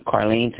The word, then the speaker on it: Carlene: I'm Carlene Kinley. – Okay. How do you spell that name? – C-A-R-L-E-N-E.